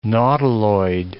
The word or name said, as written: nautiloid